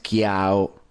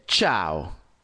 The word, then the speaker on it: ciao: Por ejemplo, no es chiao, chiao. Si dice ciao, ciao, ciao, ciao, ok? E non è chiao, ciao.